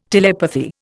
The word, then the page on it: telepathy: Indian